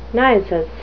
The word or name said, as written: nisus